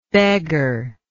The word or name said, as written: beggarhood